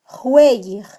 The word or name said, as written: jweyij